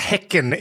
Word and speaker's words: Häcken: So it's usually pronounced Häcken in Swedish, right?